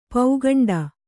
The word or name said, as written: ಪೌಗಂಡ